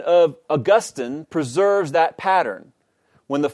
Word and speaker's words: Augustine: Second, however, while consideration of the Latin original of Augustine's name does not determine a single grammatically obligatory English pronunciation, it does suggest that Augustine is the more fitting or appropriate pronunciation. This is because the latter most closely preserves the distinctive placement of the accent in the original.